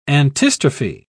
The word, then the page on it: antistrophe: British English